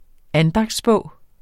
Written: andagtsbog

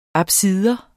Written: apsis

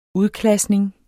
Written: udklasning

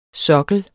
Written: sokkel

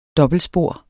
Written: dobbeltsporet

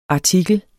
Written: artikel

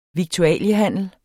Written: viktualiehandler

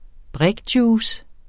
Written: brikjuice

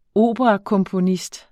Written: operakomponist

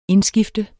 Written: indskifter

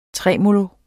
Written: tremolo

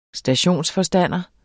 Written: stationsforstander

